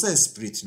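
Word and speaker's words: Esprit: من توی این مستندا دیدم که خود انگلیسی ها میگن اسپری یعنی تی آخر رو تلفظ نمیکنن. ولی من به عادت همیشگیم که از زمان بازی کارت ماشین مونده همون اسپریت رو میگم.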